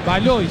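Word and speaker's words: Valois: Él dijo que Balois, dice que se pronuncia.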